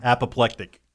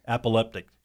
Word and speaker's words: apoplectic: How do you say it? – Apoplectic. – What did I say? – Apoleptic.